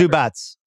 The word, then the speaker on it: Zubac: Zubats. I love calling him Zubac. As you know, I have pronunciation dyslexia, but it is Zubats.